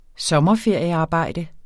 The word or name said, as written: sommerferiearbejde